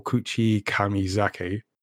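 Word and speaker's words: Kuchikamizake: One day, Taki, in Mitsuha's body, leaves the ritual alcohol, Kuchi Kamizake. Did I pronounce that right, Inko? – That's right. Yeah, it's correct.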